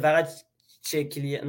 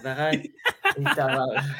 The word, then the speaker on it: Kvaratskhelia: Ok, Kvaratskhelia, ouais, bon, écoute, la saison extraordinaire de Kvaratskhelia. Non, Kvaratskhelia. – Ah, Kvaratskhelia, ok. Ok, Kvaratskhelia, c'est bon, ça. Le début de saison de Kvaratskhelia. Bon. – Faut juste que tu mentionnes, tu prononces le K.